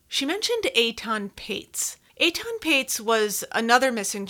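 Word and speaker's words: Patz: Eitan Paez, Pates, as some people pronounce it, whose parents have, as the Goshes have, spent endless hours searching for their lost son, and much pain.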